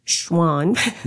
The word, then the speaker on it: Schwan's: Schwan.